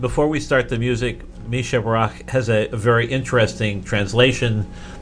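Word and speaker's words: Mi Shebeirach: And for the musical portion of today's program, we will play, and I'm going to ask to help us out with the pronunciation on this one. It's Mi Shabarach. – You did a great job.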